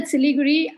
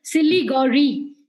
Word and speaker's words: Siliguri: So when I said Siliguri, I, he tried to pronounce it and he mm-hmm. still calls it Siligori.